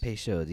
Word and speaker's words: Peychaud's: Am I saying that right? Peychaud's. – Peychaud's. Yeah.